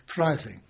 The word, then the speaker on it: Freising: So this prisoner of war camp was at Freising. – Freising.